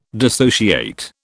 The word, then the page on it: dissociate: British English